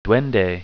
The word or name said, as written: duende